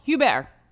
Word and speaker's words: Hebert: Is it Ashley Hebert? – Nope, Hubert. Hubert. It's French.